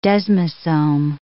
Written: desmosome

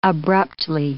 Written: abruptly

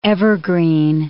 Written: evergreen

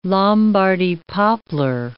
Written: Lombardy poplar

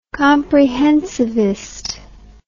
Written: comprehensivist

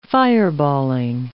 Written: fireballing

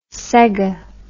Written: Sega

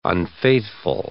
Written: unfaithful

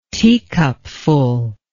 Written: teacupful

